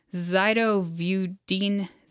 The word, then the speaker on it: zidovudine: zidovudine, known as azithromycin, AZT.